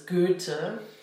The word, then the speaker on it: Goethe: Like Goethe, you might say Goethe or something, but the German pronunciation is Goethe.